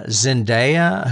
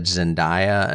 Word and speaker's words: Zendaya: Zendaya, who I always said Zendaya until I saw the Oscars the other night and they pronounce it Zendaya.